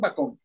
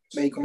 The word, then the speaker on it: bacon: Por ejemplo, los españoles dicen bacón. ¿Y qué bacón? Bacon. – Bacon.